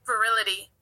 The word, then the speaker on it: virility: I just for one thing, I just want to make sure we're pronouncing it right, because now we've learned a new word today, which is good. So here we go. Get ready. – Virile. – Virility. Virility.